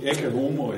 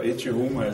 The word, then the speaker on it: Ecce Homo: Øh, så er det også fordi, at øh, undertitlen på Nietzsches øh, selvbiografi, Ecce Homo, eller Ecce Homo, det, som man nu udtaler på latin eller italiensk, den er, det er, undertitlen er, øh, hvordan man bliver den man er.